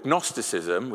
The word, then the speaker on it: Gnosticism: And then, not long after the formation of the church, an offshoot of the church came called Gnosticism, with a silent G, which is really tough to say, and they did something very similar.